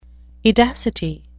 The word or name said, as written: edacity